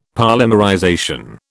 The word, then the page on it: polymerization: British English